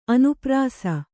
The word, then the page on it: ಅನುಪ್ರಾಸ: Kannada